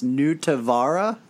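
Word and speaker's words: Nutivaara: Jake Allen made an incredible save on Marcus Nutella. No, that's not how that's pronounced. Nutavara.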